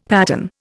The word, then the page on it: pattern: Indian